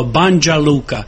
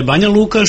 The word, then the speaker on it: Banja Luka: Međutim, ono što bih naglasio je da dosta turista koji dolaze sa stranog govornog područja izgovaraju grad Banja Luka kao Banja Luka, što je sasvim direktno vezano za engleski jezik i izgovor slova N i J u engleskom jeziku. Naravno, mi ih tad ispravimo i kažemo da je Banja Luka, što je njima u prvom trenutku i teško izgovoriti.